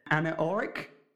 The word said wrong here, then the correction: anechoic: chamber? – Anechoic. – Yeah, there you go. Thank you.